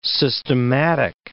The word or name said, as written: systematic